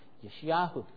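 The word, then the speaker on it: Yeshayahu (ישעיהו): you would hear it pronounced Yeshayahu.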